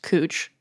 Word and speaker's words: Couch: So there's a street in Northwest Portland spelled C-O-U-C-H street. And it took me just weeks after moving here to find out it's pronounced cooch, not couch.